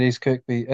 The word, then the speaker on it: East Kirkby: Um, now I, I, need to clarify something because a lot of people had told me that I was saying it wrong by saying East Kirkby, but you just said East Kirkby. It's not Kirby then.